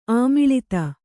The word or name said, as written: ಆಮಿಳಿತ